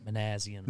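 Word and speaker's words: Minasian: Manassian.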